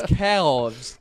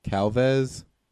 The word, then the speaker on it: calves: Hey, real quick, spell uh, C A L, or pronounce C A L. Cal. Now pronounce V E S. Vez. Put them together. Calvez. What the fuck is Calvez? You mean calves? – Oh, I'm like Calvez.